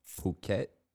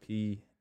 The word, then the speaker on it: Phuket: It's really called Phuket. – Yeah. – But it's spelled like fuck it on my life. And I just like saying that. – It's spelled like fuck it? – Yeah, it's like Phuket.